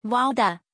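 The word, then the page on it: valda: Chinese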